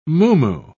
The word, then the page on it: muumuu: British English